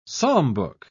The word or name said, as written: psalmbook